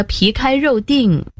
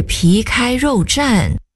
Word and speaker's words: 皮开肉绽: 唉，也不至于被打得皮开肉锭。 – 是皮开肉绽，绽字左边是绞丝旁，右边是安定的定，得读作绽。